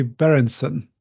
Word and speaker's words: Baerentzen: Today, my guest is Susi Baerentzen. Did I pronounce it right, Susi? – Yes, that was very excellent.